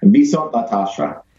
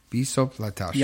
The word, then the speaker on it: Viszont látásra: Viszont látásra. – Viszont látásra. – Yeah, I'm not going to remember that. – It is spelled V-I-S-Z-O-N-T L-A-T-A-S-R-A.